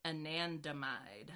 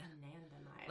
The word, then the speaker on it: anandamide: Anandamide. Anandamide.